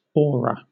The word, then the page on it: aura: British English